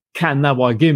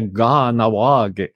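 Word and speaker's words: Kahnawake: Ouais, juste avant qu'on commence, tu nous as spécifié que le K, hein, se prononce plutôt gras, et gutturalisé, c'est presque un G. Et ça, c'est une caractéristique aussi du Mohawk. Hein, on doit pas dire Kanawagé, mais bien Ganawagé.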